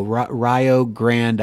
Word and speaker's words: Rio Grande: Rio Grande, I believe, is the way that you would pronounce it.